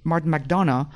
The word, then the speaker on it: Martin McDonagh: And really, as talking about little beating heart, it has none, which is a problem I usually have with Martin McDonagh. And is that how it's pronounced? I don't even know. – You did it well.